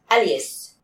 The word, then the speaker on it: Elias: Mean, the way it's written, it's the name Elias. – Elias. – But in the anime, they pronounce it Alias. – Alias? – No, Alias. They Alias? – Pronounce it Alias, like the word Alias.